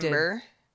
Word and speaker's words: Timbre: I think it would be Timber rejected. Timber. They're just being cheeky with their spelling.